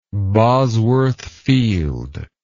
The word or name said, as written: Bosworth Field